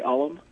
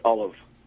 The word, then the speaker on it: Ollam: You're pronouncing it traditionally in the sense that most people do say Alam, but it's actually pronounced Olive. – Olive with a V? – Almost with a V sound, yes. – But it's spelled it's spelled O-L-L-A-M.